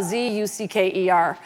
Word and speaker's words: Zucker: It's Jeff Zucker, uh, Z U C K E R. Although he wants to pronounce Zucker like hooker.